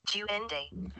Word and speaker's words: duende: Duende.